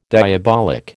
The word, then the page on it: diabolic: American English